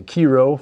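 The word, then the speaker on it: Chi Rho: So it's the, you say key row for sure, man, you pronounce it right.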